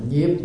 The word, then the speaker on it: nhiếp: Ai nhiếp thọ, hay là ai nạp thọ, chữ đó đọc là nhiếp mà cũng có trường hợp khác người ta đổi là chữ nạp, nhưng chữ nạp không hay bằng chữ nhiếp.